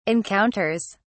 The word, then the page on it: encounters: British English